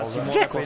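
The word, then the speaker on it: Džeko: Viene palla, Dzeko, l'ho detto bene.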